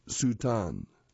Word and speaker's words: Satan: Miroslav Satan's surname is pronounced Sutan.